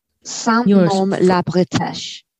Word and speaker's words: Saint-Nom-la-Bretèche: Like, our top listening places in the world this week are Boardman USA, Dublin, Ireland, and Saint-Nom-la-Bretèche. How would you say that? – Saint-Nom-la-Bretèche. Yeah. – Bretèche.